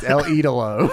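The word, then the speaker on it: El Ídolo: He said Andrade the Idol. He said El Idolo. It's El Idolo.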